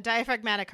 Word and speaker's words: diaphragmatic: Because what Blake had was a diaphragmatic, and I don't even know if I'm saying that right, hernia. – A diaphragmatic hernia. Yep.